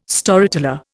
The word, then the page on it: Storyteller: Indian